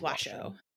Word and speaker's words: Washoe: I'm assuming it's not Washoe. – Yeah, it's Washoe.